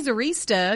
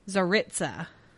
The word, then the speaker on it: Tsaritsa: I want to say Tsarista because it looks like barista, but that's not how it's spelled. Tsaritsa.